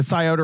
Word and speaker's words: Scioto: The Scioto River.